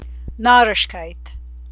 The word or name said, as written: narishkeit